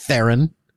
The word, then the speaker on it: Theron: I was giving Charlize the benefit of the doubt, and it turns out it actually is Theron and not Theron, as I've always been saying. So, no, it's just Theron, just like it's spelled. – Like the bird. Yeah. Theron, like a heron.